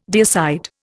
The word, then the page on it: deicide: Indian